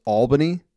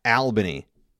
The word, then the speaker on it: Albany: It's pronounced Albany, not Albany. All right. I know there's only, it's spelled like Al. It's pronounced all.